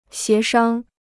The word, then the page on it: 协商: xié shāng